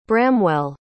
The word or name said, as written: bramwell